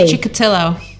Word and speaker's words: Chikatilo: Chikatilo.